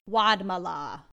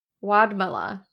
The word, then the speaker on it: Wadmalaw: Um, we're gonna do a short little bit about how do we say this island again? Wadmalaw. Wadmalaw. Wadmalaw Island.